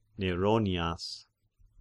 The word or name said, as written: Neronias